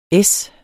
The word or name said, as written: es